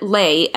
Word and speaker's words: ley: We spell it ley, L-E-Y. – Mm-hmm. – But the maps showed L-A-Y, L-E-A, L-E-E, L-E-I-G-H.